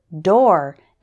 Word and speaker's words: door: The word door is pronounced like store and four.